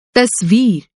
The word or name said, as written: तस्वीर